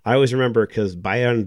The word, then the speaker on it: Bayern: Bayern? – Bayern München.